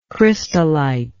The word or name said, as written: crystallitic